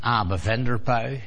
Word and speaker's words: Abe van der Puy: maar in Amerika spreek je dat uit als Abe van der Puy, Abe van der Puy, spreken ze het uit.